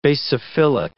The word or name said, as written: basophilic